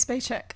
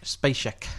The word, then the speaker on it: Spacek: The fact that the main actress, how do you pronounce her surname? – Sissy Spacek. – Spacek. – Spacek. – Spacek. Oh. Mm-hmm. Oh, nice. – We were all wrong.